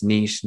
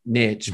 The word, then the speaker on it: niche: It's, you know, this is what you're known for, because your vibe does attract your tribe, and it is niches to riches, even though the Aussies and the Europeans would argue with me that it's niche, not niche, but niche doesn't rhyme with rich.